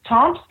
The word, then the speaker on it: Tomsk: So I did want to ask you before we run out of time as well about Tomsk. Is that how you pronounce it? It's it's very fascinating to me. Do you want to talk to some people about that as well? – Yes. Tomsk is in the center of the landmass of the Russian continent.